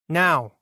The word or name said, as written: Now